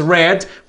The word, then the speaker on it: read: Y en su forma base es read. Y en pasado es read porque estamos utilizando también el adverbio de pasado que es yesterday. Y es por eso que tenemos que hacer una variación en la pronunciación.